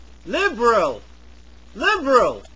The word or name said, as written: liberal